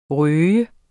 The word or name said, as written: røget